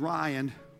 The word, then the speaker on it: Rion: Poor Denise, she's had to do this, endure this all our marriage life, because our last name is Ryan, but it's spelled R-I-O-N.